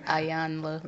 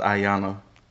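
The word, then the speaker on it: Iyanla: How you say her name? – Ayanla Van Zandt. – I thought it was Ayanna. – Ayanla. – Ayanla? Like, Ayanla.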